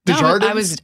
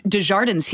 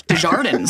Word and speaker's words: Desjardins: I used to say it English. Desjardins.